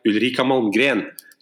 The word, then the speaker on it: Ulrika Malmgren: This week we're joined by Ulrika Malmgren.